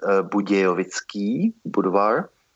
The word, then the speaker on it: Budějovický Budvar: The Czech version of Budweiser Budvar is Budějovický Budvar, as the city of Budweiser is called Budějovice in Czech.